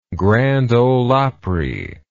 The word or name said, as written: grand ole opry